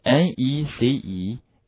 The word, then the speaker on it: necessary: 还有一个词也是类似的，大家可以看一下，就是 necessary 这个词啊，大家跟我读一下好吗？啊，这个词 necessary，注意 a 的发音是 a。